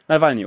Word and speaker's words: Nawalny: Nee, ich würde gerne nochmal auf den versuchten Mord an Herrn Nawalny. Ist das eigentlich die korrekte Aussprache? – Nawalny, oder? Genau. Ja.